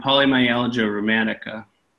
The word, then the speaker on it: polymyalgia rheumatica: Is it polymalgia? – Oh, polymyalgia rheumatica.